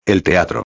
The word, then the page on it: el teatro: espanhol